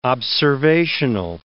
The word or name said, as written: observational